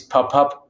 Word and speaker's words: papabile: Yes, it's papabile is the singular. Papabili is plural. That's the Italian.